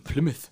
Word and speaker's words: Plymouth: Plymouth, ja, genau.